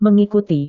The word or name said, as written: mengikuti